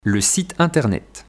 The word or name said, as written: Le site internet